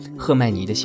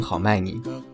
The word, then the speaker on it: 赫麦尼: 这是来自于好朋友赫麦尼的幸福。我曾经有一个阶段，一直把这个名字读成好麦尼，直到今天晚上组稿子的时候，在键盘上敲他的名字，才发现原来是赫然的赫。